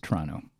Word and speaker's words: Toronto: Toronto.